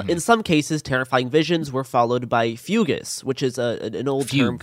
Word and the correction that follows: fugue: for, like, fugues. It says fugues. – Fugue. It's pronounced fugue.